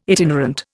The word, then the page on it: itinerant: Indian